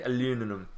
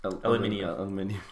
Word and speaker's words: aluminium: As the Americans would say, aluminum. Aluminium.